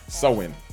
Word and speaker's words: Samhain: Halloween's origins, they date back to the ancient Celtic festival of Samhain. Samhain. Wow, I really killed that joint. It gave me the pronunciation here, and I didn't even look at it. Samhain.